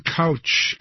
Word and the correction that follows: Koch: Koch, a major donor to conservative causes.